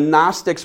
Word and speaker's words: Gnostics: So anyway, the G is silent. That's what I'm trying to get at. Dad joke tanked on that one, right? But so the Gnostics were people, the next line here in your notes is that they were people who believe they have special knowledge, oftentimes mystical knowledge.